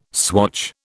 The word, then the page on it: swatch: British English